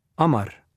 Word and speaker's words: Amar: Y-O-U-S-S-E-F. – Y-O-U-S-S-E-F. Thanks. And what's your surname, Yusuf? – Amar. That's A-M-A-R.